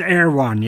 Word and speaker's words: Erewhon: So tell me about, is it pronounced Erewhon, yes? – Yes, you have it right.